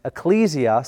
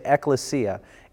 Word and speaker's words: ekklesia: The word church is mentioned in the New Testament 85 times, and the Greek word is ecclesia. Sometimes people will say ekklesia. Either way you pronounce it, this is the Greek word.